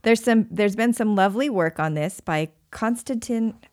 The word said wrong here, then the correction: Kostadin: Oh, I butchered that name. Kostadin Kushlev.